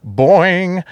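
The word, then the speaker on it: Boin-n-g: She was a regular in many of Herschel Gordon Lewis's movies, such as Nature's Playmates, Boing, Blood Feast. You have to say it that way because it's spelled B-O-I-N hyphen N hyphen G.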